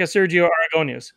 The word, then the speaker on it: Sergio Aragonés: He looks like a Sergio Aragonés. – He does. – Did I say his name right? – Yes. – Yeah.